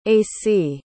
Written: acie

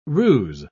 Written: ruse

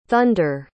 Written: thonder

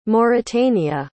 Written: Mauritania

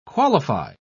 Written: qualify

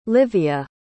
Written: livia

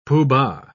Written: pooh-bah

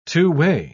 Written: two-way

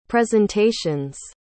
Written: presentations

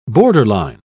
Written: borderline

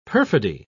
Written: perfidy

